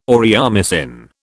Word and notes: aureomycin: British English

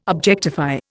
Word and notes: objectify: Indian

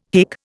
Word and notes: hic: Indian

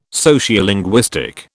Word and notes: Sociolinguistic: British English

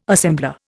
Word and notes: assembler: Indian

